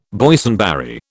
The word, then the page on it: Boysenberry: British English